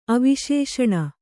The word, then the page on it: ಅವಿಶೇಷಣ: aviśeṣaṇa